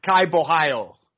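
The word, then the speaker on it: Caio Borralho: Gadzi Omar Gadziev versus Kayao Boshalo. I can't remember how to say his name. Say his name. – It's Kai Bohio.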